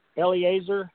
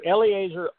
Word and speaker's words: Eleazer: Its owner, and I hope I pronounce this name right, I'll spell it for you, E-L-E-A-Z-E-R, Eliezer, Eliezer Oswald, generally spoke for the state's Republican Party, whose members wanted the Constitution ratified quickly and without any changes whatsoever.